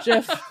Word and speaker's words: GIF: It is a soft G pronounced GIF in the story.